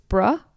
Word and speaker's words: Brough: I'm sorry, I think I said her last name was Burrow, but I think it's Bruh is how you would pronounce it.